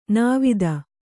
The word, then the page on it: ನಿವಾತ: Kannada